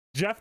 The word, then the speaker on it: Gef: Jeff the Mongoose. – It's just like a man name. I knew it. – But it's spelled G-E-F.